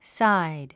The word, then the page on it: side: sáid; サァイド